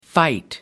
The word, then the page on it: fight: American English